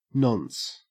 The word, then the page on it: nonce: Australian English